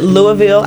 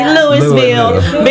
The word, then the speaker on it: Louisville: You heard that. I said Louisville. I didn't say Louisville.